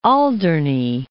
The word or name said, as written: Alderney